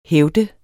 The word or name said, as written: hævd